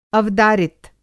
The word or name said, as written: अवदारित